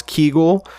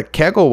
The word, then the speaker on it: Kegel: Like when she says Kegel, I didn't even know how to pronounce Kegel. I knew what a Kegel was. I thought it was called Kegel, but no, apparently, friends, it's Kegel.